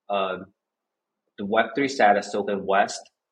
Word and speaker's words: W3ST: So first of all, it's pronounced as West. – West. Yep.